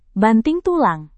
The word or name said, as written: banting tulang